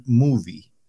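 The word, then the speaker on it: Muvi: a través del servicio de streaming Movie. Ese Movie se escribe M-U-V de burro, I de isla.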